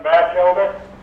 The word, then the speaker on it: Batchelder: My name is Lim Batchelder.